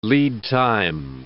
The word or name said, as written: lead time